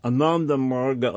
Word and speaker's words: Ananda Marga: Because what Ananda Marga appear, Ananda Marg as it is pronounced, it does not appear to be yoga oriented at all, although, yeah, ostensibly yoga, but its activities are very un-yoga-like.